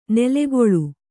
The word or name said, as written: ನೆಲೆಕೊಳ್